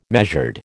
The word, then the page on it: measured: American English